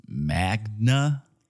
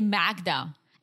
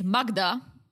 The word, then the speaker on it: Magda: So, yeah, apparently Joe thought her name was Magna, like Magna Carta. – But, dude, it's spelled with a D. – Yeah. – Why would you, like, even if you pronounce it in an American way, you would still say Magda. It's Magda in Europe.